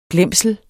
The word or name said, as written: glemsel